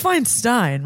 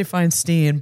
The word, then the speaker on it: Feinstein: It's Feinstein, right? I always say Feinstein, but I think it's Feinstein. – Feinstein? – Yeah. – It rhymes.